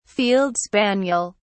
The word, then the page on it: field spaniel: British English